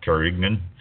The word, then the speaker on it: Carignane: Or Carignan, And that's spelled C-A-R-I-G-N-A-N-E. It's not pronounced Carignan. It's Carignan.